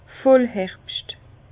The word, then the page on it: Vollherbscht: Bas-Rhin; Herrlisheim